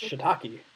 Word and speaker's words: shiitake: And, I look at them and I go, it's pronounced shiitake, and it's a mushroom.